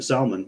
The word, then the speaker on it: Salman: So anyways, Prince Bin Talal and Mohammed bin Salman, which I also got to say, that's the only time it's okay to pronounce the L in Salman.